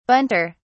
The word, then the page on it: bunter: British English